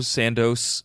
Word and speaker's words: Sandoz: S-A-N-D-O-Z. Are we cool with that pronunciation? – Sandoz. It's probably Sandoz. Sandoz. Whatever you said is fine.